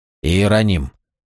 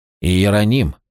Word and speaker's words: Иероним: Ерон, как оно произносится? – Иероним. – Иероним.